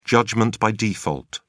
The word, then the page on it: judgement by default: British English